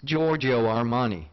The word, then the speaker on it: Giorgio Armani: Who is Giorgio Armani? – That is correct.